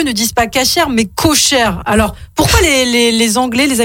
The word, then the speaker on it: kosher: En fait, c'est juste que les Américains prononcent à la sauce yiddish. – Ah, ouais. – Ils disent koisher au lieu de kasher, ah, oui.